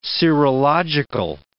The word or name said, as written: serological